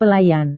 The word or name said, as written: pelayan